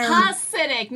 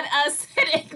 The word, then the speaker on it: Hasidic: Hasidic, not acidic.